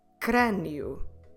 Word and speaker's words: crânio: We will also have a hard Q sound if the letter C is followed by R. CR, or in Portuguese, CR. Let's have a look at some examples. Crânio.